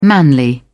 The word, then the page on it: manly: British English